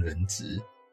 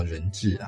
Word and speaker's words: 人质: 哦，或者是说一些老弱妇孺，那掳回去当人质啊？对不起，发音应该叫人质啊。